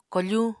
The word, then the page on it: kofuñ: Apatani